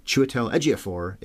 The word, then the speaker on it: Chiwetel Ejiofor: Chiwetel Ejiofor? – Yeah. I love that guy. – I think it's phonetic, if you look at it. I think that's exactly how it's said, is what I said.